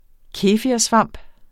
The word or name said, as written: kefirsvamp